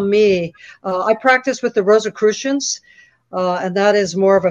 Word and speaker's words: Rosicrucians: Rosicrucians, Rosicrucian, like I'm in a spelling test here.